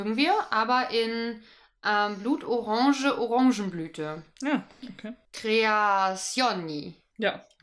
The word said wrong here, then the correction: Creazioni: Wie wird es richtig ausgesprochen? Creazioni. Ah, oh, Creazioni.